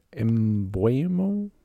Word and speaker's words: Mbeumo: Number 19 for Brentford, Mbeumo? – Yeah. – Is that how you pronounce his name? – Yeah, the winger.